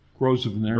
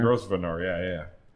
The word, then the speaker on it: Grosvenor: The Grosvenor Building. Is that how you pronounce that name? – Grosvenor. – Grosvenor, yeah.